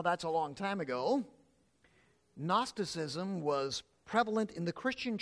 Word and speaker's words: Gnosticism: We spell it Gnosticism with a G, but it's pronounced without the G, really. In English, the term is Gnosticism.